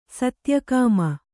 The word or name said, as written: ಸತ್ಯಕಾಮ